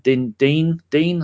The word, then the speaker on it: Digne: He's gone and got the one for the left-back from Everton, Dean, I think you say his name is. Digne.